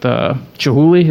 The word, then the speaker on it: Chihuly: But then, you know, I think his beef was with Chihuly.